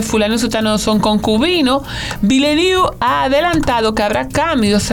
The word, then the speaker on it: Villeneuve: Villeneuve. Literalmente es como una ciudad nueva. Una ciudad nueva, Villeneuve.